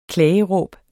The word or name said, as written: klageråb